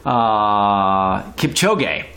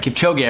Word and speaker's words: Kipchoge: So Kipchoge. That's right. Kipchoge. I remember the last name. Eliud Kipchoge was one of them.